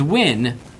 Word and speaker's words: Nguyen: If I'm not mistaken, the correct pronunciation for Nguyen is Win."